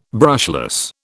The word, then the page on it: brushless: British English